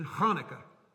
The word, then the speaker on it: Hanukkah: Or if you want to use the proper Jewish pronunciation, Hanukkah. Did I spit on something? I'm sorry. In order to say that right, you have to have some corn kernels in your throat. Hanukkah.